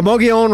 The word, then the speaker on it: Mogollon: The Mogollon Rim. Which is a real difficult way. It's like Tooele. It's like M-O-G-G-O-L-L-A-N. Nothing like it's spelt pronounced, but that's the way they pronounce it.